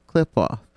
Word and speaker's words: Qliphoth: And for those that have not figured out what the pronunciation of the actual title is, it is the Qliphoth.